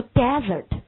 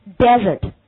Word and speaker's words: desert: 好，那因此我们今天在这里面首先来看一下作为动词 read after me dessert，但是这个词它完全可能会变为另外一个读音，读 desert，它的重音会落到第一音节上去，读 desert。